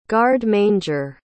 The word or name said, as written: garde-manger